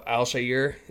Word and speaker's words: Al-Shaair: And, you know, Al-Shaair, am I saying that right? – I think you are.